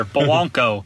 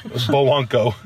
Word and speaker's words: Bowonko: And then the guys that might be playing instead of him, Howard, Cooper, Gabonga, I hope I'm saying that right. – Not even close. Wrong first letter, Bowonko. – I don't think that's right either. – It's definitely closer than Gabonga. – Bowonko.